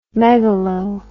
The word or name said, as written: megalo